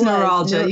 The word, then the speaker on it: neuralgia: Now, pronounce actually what it's neuralgia. You did it exactly right.